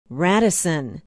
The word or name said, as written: Radisson